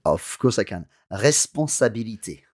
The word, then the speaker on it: responsabilité: And that's R-E, obviously, R-E-G-A-R-D-E-R. And the word responsabilité also begins with R-E, but there's no acute accent or no grave accent in there. But when you pronounce it, let's hear it. – Responsabilité. – To me, that sounds like an R-E acute.